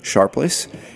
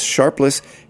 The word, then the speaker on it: Sharpless: Back in August 2009, Barclays Arena wasn't even on the horizon, but I was very interested in speaking with Bill and Chris Sharpless. And by the way, you will notice that I continually and repeatedly mispronounce their last name, calling them Sharples.